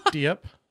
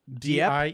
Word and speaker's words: Diep: But no, this guy, Long Jick, did I say that right? – It's like Diep. – Diep.